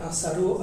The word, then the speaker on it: Asalo: Et Asalo, Asao, en fait, c'est Asalo, hein, prononcé. – Oui, oui, mais eux, ils disent Asao.